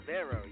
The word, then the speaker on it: Xerveo: Zevero, yes.